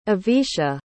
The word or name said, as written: avisha